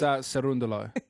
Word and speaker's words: Cerúndolo: It's Cerúndolo.